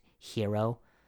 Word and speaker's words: gyro: It's spelled G-Y-R-O. And it's, it can be lamb, it can be falafel. Either way, it's a nice little Mediterranean sandwich. Okay? And it's pronounced hero.